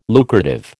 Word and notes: lucrative: American English

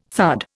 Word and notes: thud: Indian

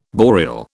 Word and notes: boreal: British English

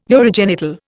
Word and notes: urogenital: Indian

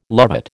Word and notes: lovat: American English

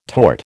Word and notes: tort: American English

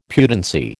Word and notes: pudency: American English

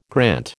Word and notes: grant: American English

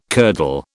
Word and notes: curdle: British English

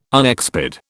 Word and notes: unexpired: British English

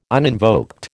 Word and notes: uninvoked: American English

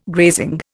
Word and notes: grazing: Indian

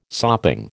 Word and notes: sopping: American English

